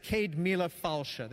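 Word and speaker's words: Ceud Mìle Fàilte: and they're pronounced like this: Cade Mila Falsha. They don't look like that, but they're pronounced like that.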